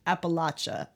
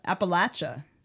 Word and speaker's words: Appalachia: but the correct way of pronouncing it is Appalachia. Appalachia. Appalachia. So you're, I'm throwing an apple at you.